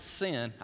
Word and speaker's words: Sein: That's how you pronounce it. S-E-I-N, but you pronounce it Sin.